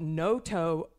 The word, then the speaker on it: Noto: The first one is called Noto Botanics, and that might be pronounced Noto. It's N-O-T-O.